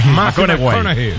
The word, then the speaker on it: McConaughey: Mateo McConaughey. – Sí, sí, sí, sí. Sí, cojones, me fichó, le gusta. Hay que ir corrigiendo la pronunciación. – McConaughey.